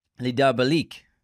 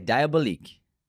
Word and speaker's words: Les Diaboliques: Les Diaboliques. Les Diaboliques. I think that's how you say it in French. – Les Diaboliques. – Les Diaboliques. – No, I think Diabolique. – I think it's Diabolique. Yeah.